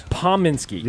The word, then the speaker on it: Pominski: What's the proper way to say it? – Pominski. – Yeah, you see, look at that. – P-O-M. – Come on, brother. – Steve Pominski.